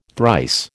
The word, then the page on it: thrice: American English